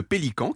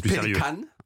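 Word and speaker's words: Pelikan: La première, c'est celle de Pélican qui fabrique les super pirates. On est tenté de les croire d'ailleurs, Pélican, parce que c'est une marque allemande. – Ah oui, ça se prononce Pelican, en fait.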